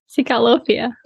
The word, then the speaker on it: Psycholophia: So let's talk about too, like your agency, and it's pronounced because it's spelled very differently. It's a very unique name, but it's Psycholophia. Am I getting it right? – Psycholophia.